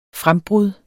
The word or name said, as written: frembrud